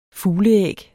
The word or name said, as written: fugleæg